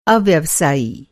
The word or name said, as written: अव्यवसायी